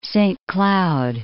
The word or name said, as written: Saint-Cloud